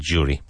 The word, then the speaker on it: jury: Yes, so judge and jury. Very good. J-U-R-Y, but pronounced with like a D, like D-J, jury, jury.